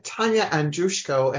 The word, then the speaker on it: Tania Andrushko: And what we have here is Tania Andrushko, and I forgot to ask, is that how I pronounce it? I should have done it. – Yes, you're all good.